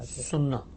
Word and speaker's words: السنہ: سنہ, اہل السنہ یعنی وہی سنت, یعنی سنت ہے نا, اس کو عربی زبان کے تلفظ میں جب ادا کریں گے تو اسی طرح ادا کریں گے.